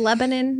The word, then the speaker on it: Lebanon: Did he say it right? It's Lebanon, Lebanon.